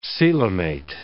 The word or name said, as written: coelomate